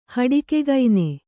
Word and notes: ಹಡಿಕೆಗೈನೆ: Kannada

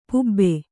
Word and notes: ಪಬು: pabu

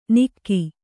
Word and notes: ನಿಕ್ಕಿ: kannada

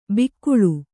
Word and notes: ಬಿಕ್ಕುಳು: bikkuḷu

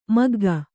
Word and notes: ಮಗ್ಗ: kannada

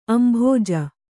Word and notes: ಅಂಭೋಜ: kannada

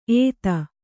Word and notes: ಏತ: ēta

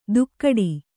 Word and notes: ತುಕ್ಕಡಿ: tukkaḍi

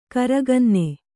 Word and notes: ಗಿರಿಕನ್ನೆ: giri kanne

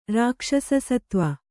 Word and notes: ರಾಕ್ಷಸಸತ್ವ: kannada

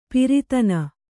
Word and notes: ಪ್ರತನ: kannada

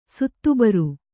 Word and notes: ಸುತ್ತುಬರು: suttu baru

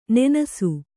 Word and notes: ನೆನಸು: nenasu

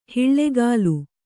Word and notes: ಹಿಳ್ಳೆಗಾಲು: hiḷḷegālu

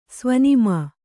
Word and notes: ಸ್ವನಿಮಾ: svanimā

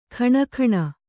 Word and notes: ಖಣಖಣ: kha'ṇakhaṇa

